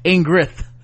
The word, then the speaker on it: Ingrid: Ingrid was, let me make sure I got her name right, because I keep saying Ingrid. It's Ingrid.